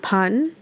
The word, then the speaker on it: pardon: พอน